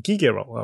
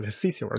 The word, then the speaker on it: Cicero: 西塞罗嘛，那古罗马的这个这个执政官，其实如果是拉丁语，应该念成 Gigero 啊，不叫 Cicero。 Cicero 是那个那个英文的念法，不是拉丁语的念法。